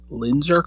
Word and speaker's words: Linzer: And I also like the Linzer, I might be pronouncing this wrong, Linzer cookies. – That is correct.